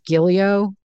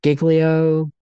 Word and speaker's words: Giglio: I pronounce this Giglio, but it's spelled G-I-G-L-I-O. It could be anything. Giglio. Who knows? It could be Giglio.